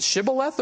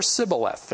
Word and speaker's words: Shibboleth: Was it Shibboleth or Sibboleth? If it was Sibboleth, they died, for they could not pronounce it right.